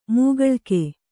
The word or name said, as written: ಮೋಕಳೀಕ